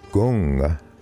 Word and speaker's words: gong: The second word, gong, pronounced G-U-N-G, or you may see it sometimes pronounced K-U-N-G, means accomplishment or skill that is cultivated through steady practice.